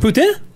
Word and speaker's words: poutine: Poutine. It's poutine. – Oh, I know. That's a... You can't say that. – Poutine? – Isn't that a That's a swear word. – Poutine? – You probably shouldn't be saying it.